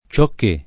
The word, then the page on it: ciocché: lingua italiana